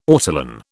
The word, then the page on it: ortolan: British English